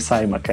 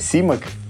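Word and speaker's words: Simak: Я потому что зашел специально перед э, записью и посмотрел, есть американский канон, как фамилии произносить, и она читается на английском у него не Саймак, а действительно Симак это не важно уже.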